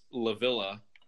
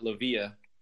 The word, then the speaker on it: La Villa Strangiato: I now know how to pronounce it, or at least I know how to pronounce La Villa, not La Via.